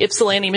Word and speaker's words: Ypsilanti: Yeah, I have in my notes, the first Domino's was in Ypsilanti, Michigan.